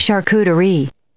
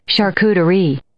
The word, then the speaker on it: charcuterie: No, but that's the way you're supposed to say it. – Mm. – Here it is. – Charcuterie. – Because they actually have a little accent on the end.